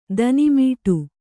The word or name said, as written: ತಣ್ಣೆಮಡೆ